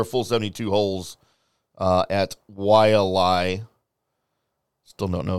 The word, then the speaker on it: Wailea: I still think it's Waylay. – No, it's not. – That's how it's spelled. – Waylay.